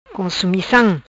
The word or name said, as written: Consumicam